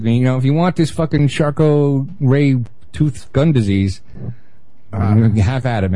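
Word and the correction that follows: Charcot-Marie-Tooth: Is that how it's pronounced? I thought it was like Charcot-ree tooth or something. – That's how Ben says it. – That's how it's spelled. – It's Charcot-Marie tooth disease.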